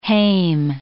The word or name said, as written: hame